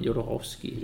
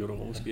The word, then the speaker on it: Jodorowsky: Jodorowsky. Jodorowsky, ja.